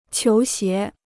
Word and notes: 球鞋: qiú xié

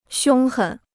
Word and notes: 凶狠: xiōng hěn

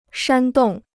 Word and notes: 煽动: shān dòng

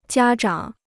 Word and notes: 家长: jiā zhǎng

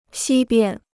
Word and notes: 西边: xī biān

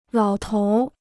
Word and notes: 老头儿: lǎo tóur

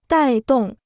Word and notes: 带动: dài dòng

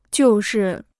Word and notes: 就是: jiù shì

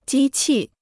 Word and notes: 机器: jī qì

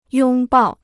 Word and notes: 拥抱: yōng bào

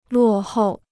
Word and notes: 落后: luò hòu

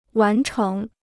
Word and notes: 完成: wán chéng

